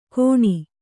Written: ಗೋಣಿ

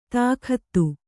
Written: ತಾಕತ್ತು

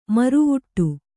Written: ಮುರವಿಡು

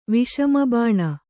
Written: ವಿಷಮಬಾಣ

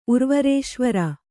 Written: ಉರ್ವರೇಶ್ವರ

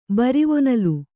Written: ಬರಿವೊನಲ್

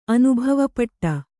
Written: ಅನುಭವಪಟ್ಟ

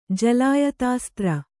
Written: ಜಲಾಯತಾಸ್ತ್ರ

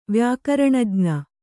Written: ವ್ಯಾಕರಣಜ್ಞ

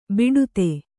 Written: ಬಿಡುತೆ

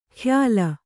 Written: ಖ್ಯಾಲ

